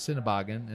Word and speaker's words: Sennebogen: Sennebogen.